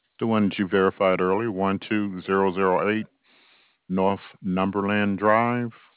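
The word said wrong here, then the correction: Northumberland: It's North Umberland. North UMBER.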